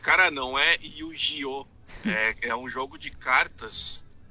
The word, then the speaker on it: Yu-Gi-Oh!: Yu-Gi-Oh! É a pronúncia correta, então, aí. – Yu-Gi-Oh!